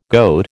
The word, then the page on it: goat: American English